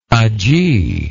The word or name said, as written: ajee